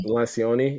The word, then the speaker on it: Lencioni: I'm going to say his last name wrong. Lencioni. – Lencioni. Yeah, he's yep.